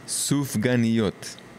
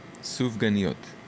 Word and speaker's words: Sufganiyot: Sufganiyot. Sufganiyot.